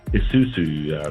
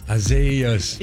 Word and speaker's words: Isaias: I see us. – No. – No, you don't see us. – Isaias.